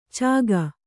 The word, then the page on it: ಚಕ್ಕು: cakku